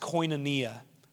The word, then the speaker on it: koinonia: This word is pronounced koinonia.